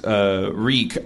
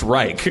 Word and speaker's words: Rieck: Their last name, my mom's maiden name is Reek, R-I-E-C-K, but it used to be pronounced Reich.